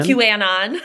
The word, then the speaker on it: QAnon: It wasn't. It's QAnon.